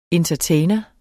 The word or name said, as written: entertainer